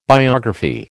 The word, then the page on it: biography: American English